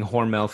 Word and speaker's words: Hormel: I remember studying Hormel Foods.